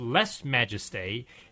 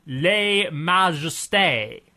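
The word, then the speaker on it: lèse-majesté: Amy Ronaldo says, Les Majestés is not pronounced Les Majestés. It's pronounced Les Majestés. With French pronunciation, or Les Majestés.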